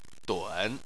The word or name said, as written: dun3